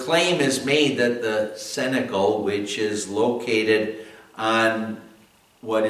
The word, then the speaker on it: cenacle: My dear fellow Christians who are following in the footsteps of our Savior, it's called the cenacle. That's spelled C-E-N-A-C-L-E, cenacle.